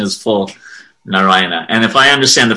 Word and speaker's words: Narayana: So it could be Narayana, like really Narayana. Short Narayana. Yeah, okay. – Narayan. So again, Narayana. – Yes, but go ahead. – But it is four syllables.